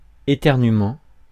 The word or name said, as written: éternuement